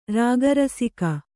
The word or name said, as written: ರಾಗರಸಿಕ